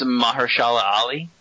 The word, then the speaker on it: Mahershala Ali: It's Mahershala Ali.